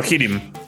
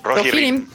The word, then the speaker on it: Rohirrim: Rohirrim. – Rohirrim.